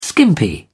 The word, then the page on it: skimpy: British English